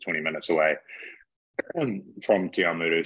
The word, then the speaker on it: Te Awamutu: Um, uh, uh, online it, it, tells me that you were born in I, I, might butcher this, but I think it's Te Awamutu on the North Island. am I right about that? – Yeah, so I was born in Te Awamutu, which is 20 minutes well, it is in the Waikato, sorry.